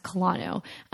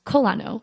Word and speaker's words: Kolano: It's, well, I always thought it was pronounced Kolano. But here they pronounce Kolano.